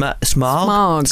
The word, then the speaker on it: Smaug: So apparently Peter Jackson pronounced it Schmaug. Schmaug. But he's got a New, New Zealand tint.